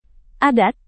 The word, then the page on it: adat: indonesian